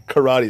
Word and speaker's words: karate: When I say karate, it's karate. So the correct way to say karate is karate.